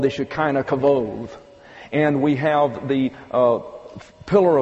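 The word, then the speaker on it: kavod (כבוד): you have K-A-V-O-D-T-H. That would be a transliteration into English and would be pronounced kavod.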